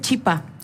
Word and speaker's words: chipa: No se dice chipá, se dice chipa.